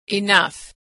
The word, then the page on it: enough: gh